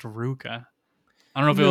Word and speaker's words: RVCA: It's pronounced Ruka. Oh my God, bro. I'm 32 years old and it's pronounced fucking Ruka.